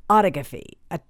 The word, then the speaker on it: autophagy: which is called autophagy. It looks like it's pronounced phonetically. It would be autophagy, but I had to look up how to say it. Autophagy, like photography, sort of.